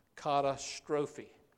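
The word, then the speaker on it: katastrophe: Kata strophe. Or if we say it like we would in English with a C, we can tell what word we get from that.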